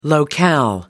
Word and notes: locale: American English

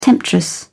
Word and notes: temptress: British English

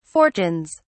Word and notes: fortunes: British English

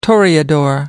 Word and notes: toreador: American English

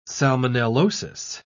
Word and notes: salmonellosis: British English

